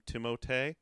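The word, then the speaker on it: Timothée: Whereas I do not share your love for Timotei. No one actually pronounces it that way, right? – No. – Okay. That's sad. – French people do.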